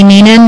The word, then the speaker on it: Nenon: For the record, my name is Emily Ninon. My last name is spelled N-E-N-O-N.